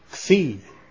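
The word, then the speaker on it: Xi (Ξ): We come to the Xi. It's called, it's pronounced like an X.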